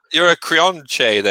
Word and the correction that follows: Creonte: then. Creonite. I never know how to say it properly. – Creonte. Yes.